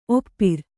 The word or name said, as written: ಒಪ್ಪಾರು